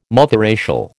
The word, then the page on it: multiracial: American English